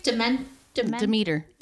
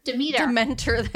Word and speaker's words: Demeter: Demeter. – Dementor. That's Dementor. It's Harry Potter.